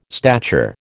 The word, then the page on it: stature: American English